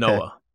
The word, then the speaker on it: Talanoa: I've got Talona Hofanga, defensive back. Am I saying that right? He's the dude in San Francisco. He's starting in the Super Bowl this Sunday, right? Fifth round Talanoa. – Pick.